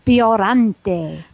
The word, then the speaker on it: Biollante: Godzilla versus Biollante.